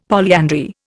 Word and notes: polyandry: Indian

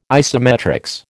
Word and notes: isometrics: American English

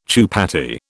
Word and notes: chupatty: British English

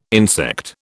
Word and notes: insect: British English

